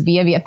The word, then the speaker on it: Vija: Yeah, on Twitter and Instagram, my moniker is Vija. Thanks for, you pronounced it right. and that's obviously V-I-A-V-I-J-A.